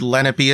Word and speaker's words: Lenape: I remember Lenape, which might be pronounced Lenape or something. I might be pronouncing it wrong. – I think technically it's Lenape, but I have heard Lenape as well.